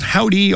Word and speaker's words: Hovvdy: That's a band called Howdy, although it's spelled with two V's and no W.